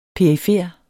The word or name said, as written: perifer